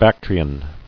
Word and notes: Bactrian: Bac·tri·an

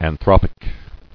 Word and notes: Anthropic: an·throp·ic

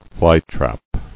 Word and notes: Flytrap: fly·trap

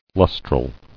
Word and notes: Lustral: lus·tral

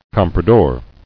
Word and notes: Comprador: com·pra·dor

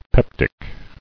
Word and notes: Peptic: pep·tic

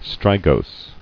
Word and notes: Strigose: stri·gose

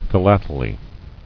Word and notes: Philately: phi·lat·e·ly

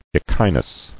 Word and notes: Echinus: e·chi·nus

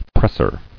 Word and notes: Presser: press·er

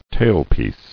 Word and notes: Tailpiece: tail·piece

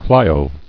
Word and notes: Clio: Cli·o

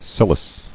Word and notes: Cilice: cil·ice

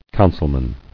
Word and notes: Councilman: coun·cil·man

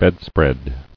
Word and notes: Bedspread: bed·spread

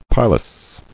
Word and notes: Pilous: pi·lous

